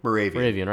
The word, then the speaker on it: Moravian: Moravian? – Moravian, right? – Yeah, okay, like cool.